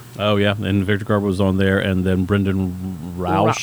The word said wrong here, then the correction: Brandon Routh: Brandon Routh. Thank you. I butchered that name.